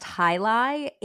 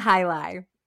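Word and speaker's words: jai alai: And it's even weirder because it's pronounced high lie. It is spelled two words, J-A-I space A-L-A-I. – Jai lie? – July? It looks like Jai a lie, but it's high lie.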